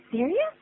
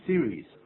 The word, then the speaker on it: serious: Are you serious? – Are you serious? 记住啊，因为你看那个字母我就可以看在 s e 的念 c，然后 r i 的念成 re。然后 OU 发成 US，所以叫 serious，而不是两音节 series，不是这样的意思。 serious 可以算是三个音节来谈了。